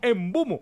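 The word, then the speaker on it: Mbeumo: Sí, entonces, ¿qué le decimos en Beumo? Pues la gente joven va a decir, pero no saben Diego, cómo se pronuncia porque es Embumo.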